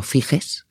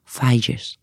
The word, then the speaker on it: Figes: Es un libro que se llama Los Europeos, a cuyo autor durante un tiempo yo llamé Orlando Figes, que es como se escribe, pero resulta que se dice Orlando Figes.